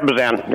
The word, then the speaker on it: Bezan: Am I pronouncing your name correctly, sir? – Yep, Bezan. Yep.